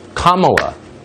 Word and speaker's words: Kamala: Kamala, like the punctuation mark, comma.